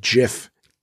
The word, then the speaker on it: GIF: If you Google that video, and it's just like, it was like a 2 second GIF, and it is pronounced GIF, not GIF for the record.